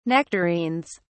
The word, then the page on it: nectarines: British English